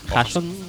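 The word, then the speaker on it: Haason: Hasson, eh, Hasson, Hasson, ¿se pronuncia Hasson? Sí. Hasson.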